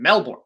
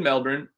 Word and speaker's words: Melbourne: Australia hosts the first Grand Slam event of the year, the Australian Open in Melbourne. Melbourne, sorry, I didn't say that right. It's hosted in Melbourne.